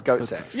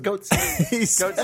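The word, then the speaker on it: Goatse: Is that nobody ever pronounces it the way the person who originally registered the domain intended it to be said. – He wanted it to be pronounced Goatsex. – Yeah, but everyone just says Goatsy. Everyone knows it as Goatsy.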